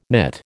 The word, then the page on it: knit: American English